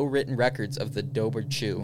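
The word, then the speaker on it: Dobhar-chú: The Dobhar Chew. Irish pronunciation that.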